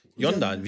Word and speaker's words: Yonder: Nee, so, wie spricht man das aus? Wonder. – Yonda, wie Yonda, genau.